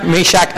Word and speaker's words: Meshach: นะคะส่วนอาจารย์วอนนะคะตอนที่ท่านพูดถึงดาเนียลชัดรักมีช้างอเปตเนโกนะคะอาจารย์วอนนี่ท่านเป็นศิษยาภิบาลผิวดำนะคะ The correct pronunciation of those words are Shadrach, Meshach, and Abednego.